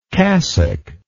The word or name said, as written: cassocked